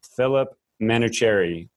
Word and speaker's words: Manoucheri: It's your host, and today I have Phillip Manoucheri with Millennium Enterprises.